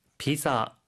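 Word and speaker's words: ピザ: はい, 피자라고 할 때, 그때 자 발음은 Z 발음이니 주의해 주시고요.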